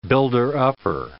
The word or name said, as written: builder-upper